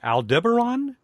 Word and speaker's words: Aldebaran: Aldebaran? Am I saying that correctly? – As far as I know.